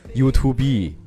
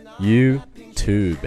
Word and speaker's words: YouTube: 前几天还有一个同学问我这个词到底怎么读，正好借这个机会来跟你们说一下，这个词千万不要再读成 YouTube。那如果你真的这么说，你就真的成为 To be number one 了。千万要注意它的读法是 YouTube。 YouTube。那除了 YouTube。